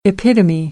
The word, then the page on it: epitome: American English; ɾ - t